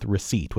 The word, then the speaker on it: receipt: That doesn't necessarily mean that I'm right, but I want to go with receipt, with that silent P in there, because who ever thinks there should be a silent P? – Well done. Again, receipt was spelled R-E-C-E-I-T-E, but then they added the P to bring it in line with the Latin root recepta.